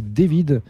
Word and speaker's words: D4vd: Alors, Deforvidi, il faut le prononcer David.